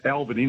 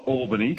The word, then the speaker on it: Albany: It's pronounced Albany, not Albany.